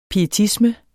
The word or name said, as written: pietisme